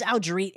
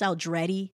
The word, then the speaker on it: Aldrete: It's it's Aldrete if you're white. It's Aldretti if you're Italian. It's uh, spelled like Aldretti.